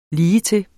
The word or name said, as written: ligetil